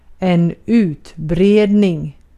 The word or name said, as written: utbredning